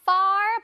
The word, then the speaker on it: far: 이거 far 발음 잘해야 돼. 팔 아닙니다. 팔 아니고요. far. 자, F니까 F. far. 발음 잘해주세요.